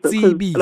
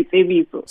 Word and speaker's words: Tsebiso: But also my name is Tsebiso, not Tsebiso. Tsebiso. A lot of Zulu people say Tsebiso.